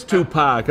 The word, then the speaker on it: Tupac: It is Tupac.